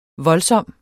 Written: voldsom

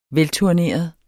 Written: velturneret

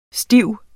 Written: stift

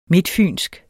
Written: midtfynsk

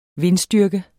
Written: vindstyrke

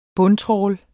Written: bundtrawl

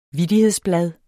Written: vittighedsblad

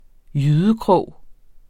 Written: jydekrog

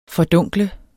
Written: fordunkle

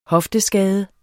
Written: hofteskade

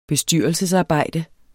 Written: bestyrelsesarbejde

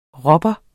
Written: rubber

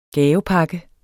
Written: gavepakke

